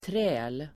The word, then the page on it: träl: [trä:l]